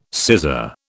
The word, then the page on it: scissor: British English